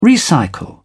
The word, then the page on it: recycle: British English